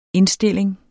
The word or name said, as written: indstilling